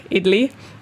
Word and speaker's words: idli: Like, guys, firstly, if those of you who want to Google and are curious what exactly are these two talking about, it's spelled I-D-L-I, Italy.